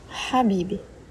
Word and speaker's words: хабиби: Эту букву очень легко произносить, но очень трудно распознавать. Вы же 100% знаете одно слово с этой буквой, с этим звуком. Это слово, которое вы часто слышали в песнях арабских – «хабиби», что означает «дорогой» или «возлюбленный».